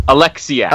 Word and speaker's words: Oleksiak: Penny Oleksiak, is it? – Oleksiak. – Oleksiak, that was my second guess.